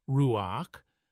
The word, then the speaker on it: ruach: The Hebrew word ruach, often written ruah and pronounced roughly ruach,